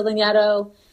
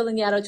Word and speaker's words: Lanyadoo: And because it's really stupid that I would use my last name to like, you know, make people find me, I'll spell it. It's L A N Y A D O O.